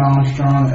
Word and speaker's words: Armstrong: I'm Tom Krebs, Assistant Director of the FCIC, and that's K-R-E-B-S. – John Armstrong, A-R-M-S-T-R-O-N-G, FCIC. – And Bob Hinckley, H-I-N-K-L-E-Y, with the staff of the FCIC.